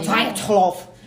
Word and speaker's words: Dyatlov: Dyatlov, sei lá como é que fala. É, eu tô inglesando muito um negócio que é russo, né? Você quer, você é, quer falar russo? É Dyatlov. É, é isso mesmo.